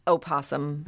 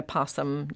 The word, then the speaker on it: opossum: You can say either one. Scientists will necessarily spell it with the O, opossum. But that O is sometimes silent. So you can spell it opossum and pronounce it possum.